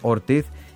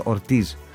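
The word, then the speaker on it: Ortiz: Εγώ βέβαια θα διορθώσω όλους αυτούς το 99% επειδή είναι Πορτορικανός, εκεί διαβάζεται ως Ορτίζ. – Λοιπόν. Ναι, πάντως εμείς στην Ελλάδα από τις περιγραφές, ναι, των speaker, ναι, το μάθαμε Χωσέ Πικουλίν Ορτίθ. – Ναι, επειδή το διαβάζουμε την ισπανική προφορά, αλλά δεν παίρνει ισπανική προφορά.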